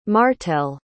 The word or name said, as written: martell